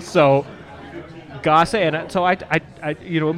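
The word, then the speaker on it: Gose: Okay, so the bartender first said, well, the correct pronunciation is gosse. Gosse. – Gosse? – And I was like, are you sure about that? He's like, yes. Very adamant. Like, I know its correct pronunciation is gosse.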